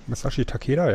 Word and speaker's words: Masashi Takeda: Der würde gern das Match Masashi Takeda, spricht man das Masashi? – Masashi Takeda, ja.